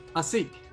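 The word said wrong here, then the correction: Assem: Asim. – Asim. Okay. – I believe it's spelled A-S-S-E-M.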